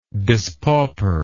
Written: dispauper

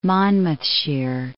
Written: Monmouthshire

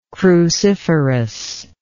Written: cruciferous

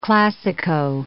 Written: classico

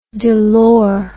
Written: delors

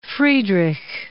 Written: Friedrich

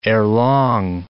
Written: erelong

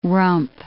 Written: rump

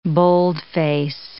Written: boldface

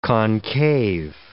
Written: concave

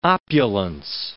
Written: opulence

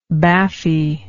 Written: baffy

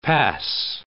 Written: pass

